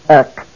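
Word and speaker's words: ek (ἐκ): It has the smooth breathing mark, therefore there is no H sound. It is pronounced ek.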